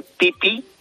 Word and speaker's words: Phi-Phi: Y luego, para los amantes de las películas de James Bond, pues aunque está lleno de turistas, por favor, irlas a las islas Phi-Phi, que se escribe Pipi, Phi-Phi,